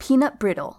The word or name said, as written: peanut brittle